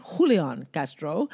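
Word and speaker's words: Julián Castro: So Julian Castro, or Julian Castro, depending on your background. I'm Black and Latina, so I should say it properly. So I'm going to say Julian Castro.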